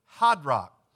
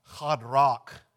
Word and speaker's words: Hadrach: Now this mention of I think it's pronounced Hadrach in the Hebrew in verse 1 there.